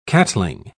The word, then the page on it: catling: British English